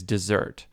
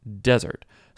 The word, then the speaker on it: Dessert: Her last name, her maiden name, is Dessert. When you look at it, people are going to say dessert because it's French, but it's pronounced desert.